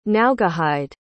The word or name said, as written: naugahyde